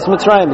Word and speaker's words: Mitzrayim: That's a Masorah that we have, that the proper pronunciation is Mitzrayim.